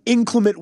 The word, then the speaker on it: inclement: Inclement weather. Inclement weather. Yes. Inclement weather. So you guys put the you put the accent on the first syllable.